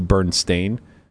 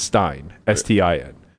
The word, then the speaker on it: Berenstain: So the Berenstain Bears, I believe, is actually spelled Stain, so Berenstain. It's not pronounced that way, but it's all. – But everybody remembers it as Stein, Stein.